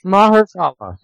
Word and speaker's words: Mahershala: Mahershala. Yeah, Mahershala Ali. – That is, you know what? It's funny. I overthought it. It's perfectly phonetic, which is almost never the case.